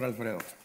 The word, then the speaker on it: Alfredo: Mi nombre, como dice el pastor, que lo pronunció muy bien, soy el pastor Alfredo.